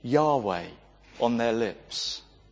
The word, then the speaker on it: Yahweh: In the Hebrew Bible, it's represented by the four letters, YHWH, that we pronounce Yahweh.